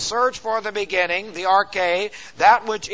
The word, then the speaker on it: arche: Now here is the arche. A-R-C-H, long E.